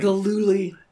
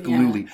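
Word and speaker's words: Gillooly: Galooly. – Thank you, Jeff Galooly.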